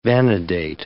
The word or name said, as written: vanadate